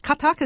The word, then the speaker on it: Kathak: This is it Kathak? Is that how it is pronounced? – That's right, yeah.